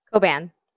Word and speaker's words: Coban: Coban.